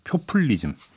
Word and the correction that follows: 표퓰리즘: 표퓰리즘. 그렇죠. 예.